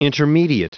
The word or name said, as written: intermediate